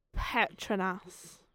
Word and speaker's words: Petronas: Say it like that. It's Petronas.